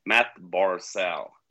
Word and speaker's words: Barzal: Och då var det ju en av våra följare på Twitter som sa att förstod inte varför vi uttalade Matt Barzal. Men då skrev du att ja, men det är så han uttalat sitt namn själv. Och så gick jag in på Elite Prospects där och pronunciation och spelade in en snutt Matt Barzal. Yep. Och så skickade jag till honom. Och då fick jag ju, oj då, det är så du uttalar.